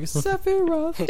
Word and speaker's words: Sephiroth: Well, you know, when it came out in 1997, none of us knew how to pronounce it. We're like, yeah, Sephiroth. But it's like, Sephiroth.